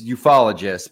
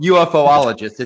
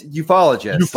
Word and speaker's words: ufologists: It's ufologists, but you can call it UFOologists if you'd like. – Is that really how you pronounce it? – Yeah, it's ufologists.